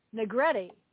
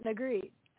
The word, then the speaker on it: Negrete: So Matt's last name is Negrete, not Negrete. And I apologize. It's spelled N-E-G-R-E-T-E.